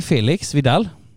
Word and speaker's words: Felix Vidal: Och också varmt välkommen till dig, Felix Vidal. Jag uttalar ditt namn rätt? – Absolut.